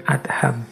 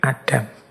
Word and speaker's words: Adham: Membacanya memang bin Adham, bukan bin Adam.